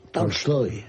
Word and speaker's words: Tolstoy: No es exactamente Tolstoy, es Tolstoy. Tolstoy. – ¿Cómo? – Lo dije con Tolstoy, con una... – Embajador, lo dije yo así tal cual, Tolstoy. – Correcto, es una A que tira hacia el O, pero que es A.